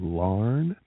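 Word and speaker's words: Laugharne: From there, we worked our way over to Larne, which like is, yarn. It took me a little while to learn that one. – It doesn't look like it should say Larne, but that's how they say it.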